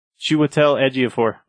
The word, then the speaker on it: Chiwetel Ejiofor: M-O-R-D-E-A-U. – M-O-D-O-R. How do you say his name? You just said it perfectly. – Chiwetel Ejiofor.